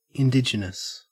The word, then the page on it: indigenous: Australian English